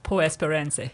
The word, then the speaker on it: Por Esperanza: And the song I've chosen is, forgive me on my pronunciation, Por Esperanza? – Por Esperanza. – Yep.